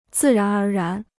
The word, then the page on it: 自然而然: zì rán ér rán